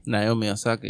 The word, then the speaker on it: Naomi Osaka: Naomi Osaka, did I say it right? – Naomi Osaka, yep.